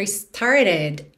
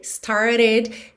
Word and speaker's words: started: Lembrando que esse E aí da palavra started é pronunciado, ok? E esse T, o segundo T, started, ficou reduzido para rarara, muito comum na pronúncia americana. Também poderia ser started, alright?